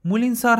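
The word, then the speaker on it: Moulinsart: مولینسار هم تلفظ فرانسوی این کلمه هست و نوشتنش یه ذره فرق داره و نوشته میشه مولینزارت یعنی آخرش کلمه آرت رو داره مولینز آرت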